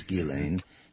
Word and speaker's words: Ghislaine: Ghislaine. – Ghislaine, okay. Ghislaine. Good, yeah. – And some say Ghislaine, but it's Ghislaine.